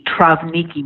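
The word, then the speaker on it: Trawniki: Yes, it's actually pronounced with a V, Travniki men, though it doesn't have a V.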